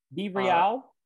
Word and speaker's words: BeReal: BeReal. – I like to pronounce it like it rhymes with cereal.